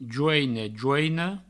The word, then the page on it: joine, joina: jouine, jouino; Provençal